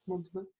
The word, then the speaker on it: Montauban: I was playing in Montauban in France, that's how you say it, Montauban, on the LET Access Tour.